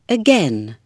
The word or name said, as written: again